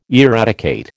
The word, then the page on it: eradicate: American English